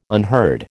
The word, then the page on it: unheard: American English